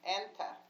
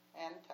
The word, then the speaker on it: Anta: In Europe, it was Anta. Anta, I don't know how you can spell that, A-N-T-A, Anta.